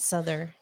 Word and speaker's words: Souther: Yeah, it's Souther.